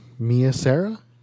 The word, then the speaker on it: Mia Sara: It's Mia Sara.